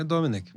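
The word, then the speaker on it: Domènec: Como é que é a pronúncia? É Dominic. Dominic.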